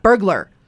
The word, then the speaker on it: burglar: All right, there's no U in the middle there. It's not like the word regular. It's burglar.